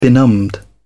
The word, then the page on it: benumbed: British English